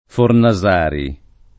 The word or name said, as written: Fornasari